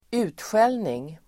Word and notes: utskällning: [²'u:tsjel:ning]

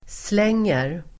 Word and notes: slänger: [sl'eng:er]

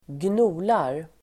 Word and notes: gnolar: [²gn'o:lar]

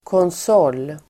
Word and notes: konsol: [kåns'å:l]

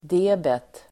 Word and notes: debet: d'e:bet

